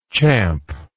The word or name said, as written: champy